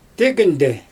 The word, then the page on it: tegynde: Xyzyl